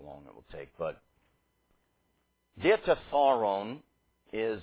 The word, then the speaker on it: Diatessaron: Diatessaron is the way that you say this word. So the accent is on the penult. The penult is the syllable before the ultima, the last syllable. So it's the second to last syllable.